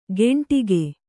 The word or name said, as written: ಗೆಂಟಿಗೆ